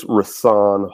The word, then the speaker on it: Rahsaan: It's Rahsaan Hall. The H is silent.